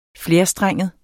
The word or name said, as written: flerstrenget